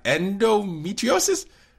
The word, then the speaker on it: Endometriosis: Endometriosis.